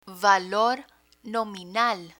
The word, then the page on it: valor nominal: Spanish